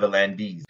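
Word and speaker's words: V'landys: You pronounce it as though you're English at the end. You say Peter Volandis. It's Peter Volandis.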